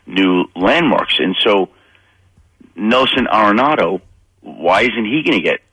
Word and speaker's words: Arenado: Nelson Arenado? Am I saying that? Yes, Arenado. – Nolan Arenado.